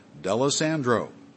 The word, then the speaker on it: D'Alessandro: Phonetically, it's spelled D-E-L. Dash A dash S-A-N-D dash R-O-W, D'Alessandro, or other people simply call me Mr. D, and I answer to either one.